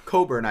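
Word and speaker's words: Cockburn: Coburn, I believe it's pronounced.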